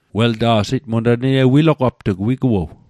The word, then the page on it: wilogapt'g: Mi'gmaq